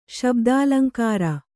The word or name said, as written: ಶಬ್ದಾಲಂಕಾರ